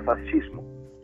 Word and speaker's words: fascismo: Bueno, la palabra fascismo, que en, en italiano es bien pronunciado, sería fascismo porque la, C se pronuncia como nosotros lo deberíamos pronunciar como CH.